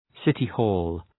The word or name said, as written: city-hall